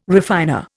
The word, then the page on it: refiner: Indian